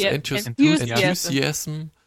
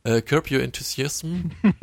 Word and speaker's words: Enthusiasm: Fox, Curb Your, wie spricht man das aus? – Enthusiasm. – Curb Your Enthusiasm.